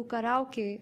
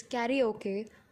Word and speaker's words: karaoke: But more than that, I came to Abeno Harukas to do karaoke with my friends. Now, I know in English the word is karaoke, but it's a Japanese word originated from Japanese, which is pronounced as karaoke.